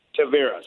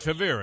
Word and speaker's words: Tavira: And the last one, there's really nothing to, the east of, how do you say this town? Cavish? Tavira, sorry. – Tavira, we're in Tavira right now.